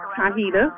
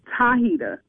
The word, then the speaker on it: Tahita: Tahita. – Hi, Hida. – Yes. – Am I pronouncing that right? Hi, Hida. – Tahita with a T. – Oh, well, Hi-hita. All right, baby. – Tahita.